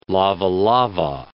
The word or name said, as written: lavalava